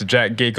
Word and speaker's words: Jac3dG33k: For those that know me as Jack Geek on Instagram. And if you don't know me yet, it's spelled J-A-C-3-D-G-3-3-K.